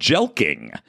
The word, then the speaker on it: jelqing: Of course, dick maxing has two X's in it, and there's a particular technique that they have glommed onto, and it is called jelking. That's spelled, for those of you at home, it is spelled J-E-L-Q-I-N-G, and it is literally stretching out your penis, and apparently the derivation of...